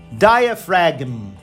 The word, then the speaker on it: diaphragm: Outra é o nosso diafragma, nosso músculo que nos permite respirarmos, que ele se escreve D-I-A P-H-R-A-G-M. Esse g m A pessoa não fala diaphragm. A pessoa vai falar diaphragm.